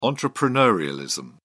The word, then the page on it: entrepreneurialism: British English